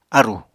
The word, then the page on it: árù: Apatani